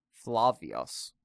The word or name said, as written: Flavius